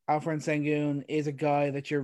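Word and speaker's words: Şengün: Sengun, we should start saying that. That's how it's said.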